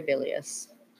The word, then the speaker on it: bilious: She's very bilious. – Very bilious. – I hope we're saying that right. – You are.